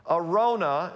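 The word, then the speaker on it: Araunah: Arona is how you pronounce that.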